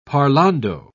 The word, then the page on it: parlando: British English